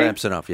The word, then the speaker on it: Samsonov: But the Leaf goaltender, Samsonov, is that how you pronounce his name? – Samsonov, yes.